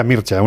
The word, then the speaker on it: Mircea: Rumana, él se llama Mircea Eliade, sí, se pronuncia Mircea, uno que es muy friki de todos los rumanos, ya lo sabéis.